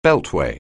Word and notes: beltway: anglais

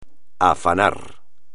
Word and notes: afanar: espagnol

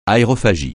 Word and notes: aérophagie: francais

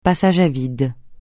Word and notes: passage à vide: francais

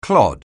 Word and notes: clod: anglais

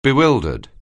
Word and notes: bewildered: anglais

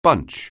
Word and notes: bunch: anglais

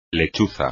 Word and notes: lechuza: espagnol

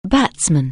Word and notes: batsman: anglais

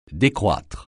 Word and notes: décroître: French

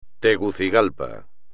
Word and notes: Tegucigalpa: espagnol

